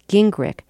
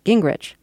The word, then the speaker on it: Gingrich: By the way, Candace pronounces her last name Gingrich. She says Newt changed the pronunciation after moving to Georgia, where everyone else pronounced it Gingrich.